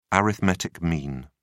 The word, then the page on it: arithmetic mean: British English